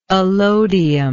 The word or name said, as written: alodium